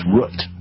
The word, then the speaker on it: root: That's going to make some You know you have an accent when you say root beer. – Root beer. – No, that's the proper way to say it. Those people listening in Idaho know that Fred is saying it perfectly. It's root.